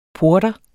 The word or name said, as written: porter